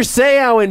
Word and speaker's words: Seau: Seau, I said, yes, you're pronouncing it right. Because as you will always remember, when Junior Seau hits you, you say, ow.